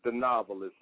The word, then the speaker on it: Thanovelist: Well, my name is The Novelist, and you spell it T-H-A-N-O-V-E-L-I-S-T.